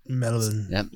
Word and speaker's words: Melbourne: Melbourne.